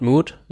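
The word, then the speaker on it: Mout: Stimmt, auf der Webseite steht Old Mood, in Klammern, rhymes with fruit.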